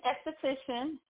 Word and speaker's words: esthetician: Is it esthetician? What is it? – It's esthetician.